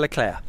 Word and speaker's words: Leclerc: I mean, do we pronounce the C? Do you like pronouncing that second C? – No. – Okay. – We pronounce it properly. It's just Charles Leclerc. – Charles Leclerc.